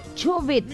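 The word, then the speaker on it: Chovet: Estamos escuchando desde Chobet, se llama el lugar. Chobet. Está bien pronunciado, Walter.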